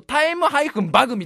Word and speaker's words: time-bug: なんでちょっと読むとタイムバグみたいな感じになりますけど、